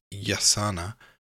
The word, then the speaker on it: jacana: A still more interesting bird was the jacana, as it is spelt in books but pronounced yasana by the Indians of Paraguay, a quaint rail-like bird supposed to be related to the plover family, black and maroon-red in colour.